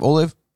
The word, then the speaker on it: Olave: I like Chris Olave. Am I saying that right for his last name? Olave? – Olave.